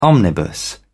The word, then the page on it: omnibus: British English